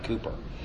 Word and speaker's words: Cowper: Spelled Cowper, but I think you pronounce it Cooper.